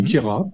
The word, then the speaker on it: Queyras: C'est dans le Queyras, dans le Quéra, pardon, ouais. On prononce pas le S, attention, je vais me faire des ennemis.